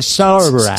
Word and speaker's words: Zara: Ah, que lo dice bien. – Sí, sí, sí. – Se dice así. – Se dice así. Lo que pasa es que aquí en España somos un poquito más cazurretes, y decimos Zara, no es Zara, O sea, lo ha dicho muy bien.